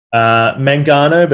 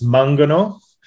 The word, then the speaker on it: Mangano: Mangano, but in Italian it's Mangano.